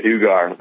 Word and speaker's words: Uygur: I just know the Uygur, the ogre, last part. Ogre.